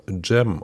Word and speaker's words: Cem: Äh, unser ehemaliger Trainer in Babelsberg hieß ja Cem oder Cem Effe, ne? also C-E-M. – So. Mhm. – Und der wird zum Beispiel auch Jam ausgesprochen. Und nicht Cem, wie man vermuten würde.